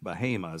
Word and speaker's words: Bahama: Well, in North Carolina, there's a city that's B-A-H-A-M-A, and it's pronounced Bahama.